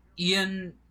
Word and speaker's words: Ioan: His real name is like Ian Gruffudd, but it's like spelled like I-O-I-N or something like that, or I-O-A-N.